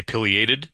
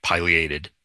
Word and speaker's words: pileated: But I say pileated, and I just learned, or maybe relearned, that that's not correct.